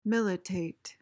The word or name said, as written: militate